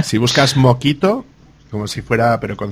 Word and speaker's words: Mockito: Entonces ahí, por ejemplo, en el caso de Java utilizan uno que se, se escribe mojito, M-O-C-K-I-T-O, pero que realmente se llama mojito.